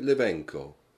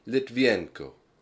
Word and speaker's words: Litvinenko: Yes, it's Dmitry Levenko. – I'm sorry, but could you spell that for me, please? – Dmitry. D-I-M-I-T-R-I. Litvienko. L-I-T-V-I-N-E-N-K-O.